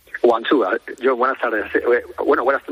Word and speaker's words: Guangzhou: Sí, como ya he dicho, se dice Guangzhou. Bueno, se dice Guangzhou, but normalmente aquí le damos Guangzhou.